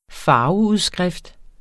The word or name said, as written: farveudskrift